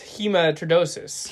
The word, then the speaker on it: hematidrosis: Can you pronounce that? – Oh, yeah. Apparently it says hematidrosis. – Hematidrosis. Your pronunciation, I promise you, is far better than mine on that.